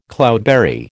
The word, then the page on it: cloudberry: American English